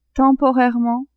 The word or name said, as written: temporairement